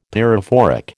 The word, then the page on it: pyrophoric: American English